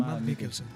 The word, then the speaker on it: Mads Mikkelsen: Y de ahí sale Mads Mikkelsen, o como se pronuncia. – Sí, sí, Mads Mikkelsen.